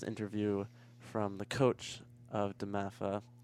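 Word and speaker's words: DeMatha: So Imhotep and Damatha, I said that right? – Yep.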